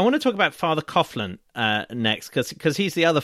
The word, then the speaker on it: Coughlin: Well, I've been schooled that we pronounce it Coughlin with a hard Coughlin.